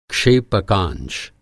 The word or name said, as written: क्षेपकांश